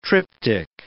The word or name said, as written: tryptic